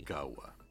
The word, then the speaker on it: cahwa: La palabra café viene del árabe kawa, que significa revitalizante. – ¿Cómo se escribe? – Kawa. – Ajá. ¿C-A? – C-A-H-W-A.